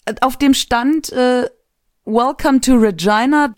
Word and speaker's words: Regina: Das ist der inoffizielle Slogan von Regina Saskatchewan. – Regina. – Regina, mein Fehler. Jedenfalls Ryan Reynolds ist in Schwierigkeiten geraten in Regina. – Ja, weil sonst reimt es sich nicht.